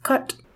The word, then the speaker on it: cait: The word Kate, C-A-I-T, also in Irish, means cats. It's pronounced cut.